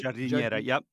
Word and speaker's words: giardiniera: Giardiniera.